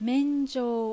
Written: Menjou

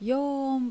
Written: Youon

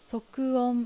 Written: Sokuon

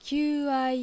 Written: Kyuuai

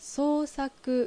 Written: Sousaku

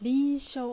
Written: Rinsho

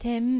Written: Ten

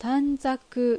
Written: Tanzaku

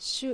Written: Shu

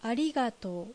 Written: Arigatou

